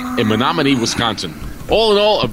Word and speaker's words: Menominee: What's the Menominee. – Menominee, Wisconsin.